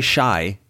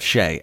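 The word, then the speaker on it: Shai: You know, that actually helps me because there's been times on this podcast and in life where I say shy instead of Shay, and I could actually use some sort of like wordplay to lock it in how it's pronounced, and Shea Gay gets me there. Shea, word like Shea. Gay? – Yes, exactly. Like Rudy Gay, Shea Gilgis Alexander, Shea Gay. Great name, super short, and it rhymes.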